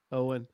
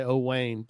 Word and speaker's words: Owen: Owen's with us, and I'm pronouncing it correctly. I was not sure, so I had to ask Owen because my Southern would want to come out and say Owayne, but it's more fun when you butcher people's names, especially when they're on the show.